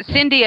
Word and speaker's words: Cyndy: So Cindy as in Cindy Lauper, not as in Cindy Crawford, C-Y-N-D-Y. And Darnell, D-A-R-N-E-L-L.